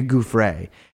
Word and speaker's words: Giuffre: But his whole thing is he's trying to discredit Virginia Giuffre, who we pronounce her name, Virginia Giuffre.